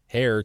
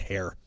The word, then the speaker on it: heir: The Duke of Sussex, 38, wrote many shocking anecdotes about his life as a spare heir to the British throne. Did I say that right? – Spare heir? – Heir. I said hair.